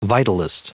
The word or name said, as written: vitalist